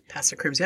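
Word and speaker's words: Påskekrim: is known as the tradition of Passacrims. I hope I pronounced that right. – Passacrims, yep.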